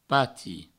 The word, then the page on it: pati: provencal